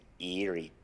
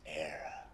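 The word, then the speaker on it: Éire: The land to your fathers isn't Erie, Boyle. It's Era.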